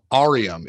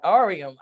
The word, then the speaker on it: Aureum: It's called Aureum. It's actually derived from Latin for gold. – Oh, I like that. Aureum.